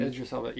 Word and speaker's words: Idris Elba: How do you say it? – Idris Elba? – Mm-hmm. Idris Elba, yeah. – Am I correct in that? – Yep, no. Okay. Yeah, Idris.